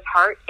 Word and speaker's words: Hartte: Yeah, so my name is Michale, M-I-C-H-A-L-E, and the last name is Hart, H-A-R-T-T-E.